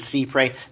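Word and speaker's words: cy-près: The proper pronunciation is Cypre, notwithstanding however many judges get it wrong. It's law French. There is no long Y in French.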